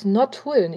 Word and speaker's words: Nottuln: Und damit begrüße ich Dirk aus Nordhulen. Ich hoffe, ich habe es richtig ausgesprochen. – Ja, das passt schon ganz gut.